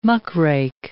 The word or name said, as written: muckrake